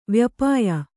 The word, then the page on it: ವ್ಯಪಾಯಿ: vyapāyi